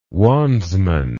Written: wandsman

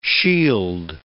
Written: shield

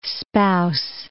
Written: spouse

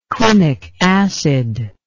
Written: quinic acid

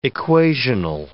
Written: equational

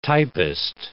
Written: typist

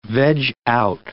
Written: veg out

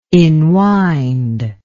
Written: inwind

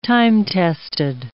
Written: time-tested

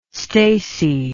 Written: stacy

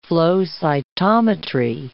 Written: flow cytometry

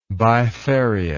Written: bifarious

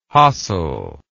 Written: hassel

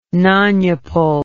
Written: nonuple

